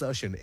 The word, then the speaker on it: Sershin: Everyone's calling me Sachin, Sachin, Sachin. Okay, but I'll take it. But so it's Sachin, S-E-R-S-H-I-N. But I'll take it as Sachin because back in school days, they used to call me Sachin.